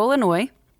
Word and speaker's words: Illinois: They're with Beyond the Blonde Band out of Chicago, Illinois, without an S. So it's in Illinois, not Illinois, Texas, because believe me. The S is silent, which is weird, but it's silent.